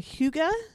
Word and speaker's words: hygge: So, but spell it because I did not know that's how it was pronounced. And so I never would recognize it. – It's H-Y-G-G-E.